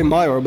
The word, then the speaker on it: Mior: Mior, yeah. Okay, that's actually correct. Yeah, most people say Mior, but it's pronounced Mior.